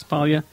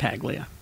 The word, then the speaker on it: Paglia: And I don't trust anyone who has a silent G in their name. – I said Paglia, didn't I? – But I think, Camille Paglia. – Paglia. – It's Paglia. Make it Paglia. You don't deserve the silent G.